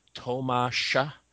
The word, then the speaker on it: Tomasz: It's pronounced Tomasz.